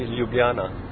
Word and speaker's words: Ljubljana: What's the right way to pronounce the city? – No, the right pronunciation would be Ljubljana.